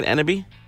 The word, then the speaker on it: Enneby: Am I pronouncing that right, Enneby? – Yes, you are.